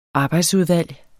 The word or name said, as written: arbejdsudvalg